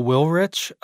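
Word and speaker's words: Willrich: My name is Michael Wilrich.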